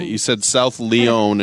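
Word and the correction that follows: South Lyon: and it's South Lion.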